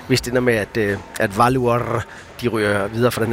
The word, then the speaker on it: Valur: Hvordan er det med, du udtaler det? – Valur. – Valur. Valur fra Reykjavik. Det er nemlig rigtigt.